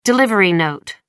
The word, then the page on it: delivery note: American English